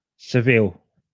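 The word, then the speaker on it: Sevilla: Right, that's how you say it. – Seville. – Seville. I was hmm. close.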